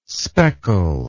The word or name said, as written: speckle